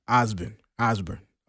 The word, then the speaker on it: Ozbirn: Ozbirn.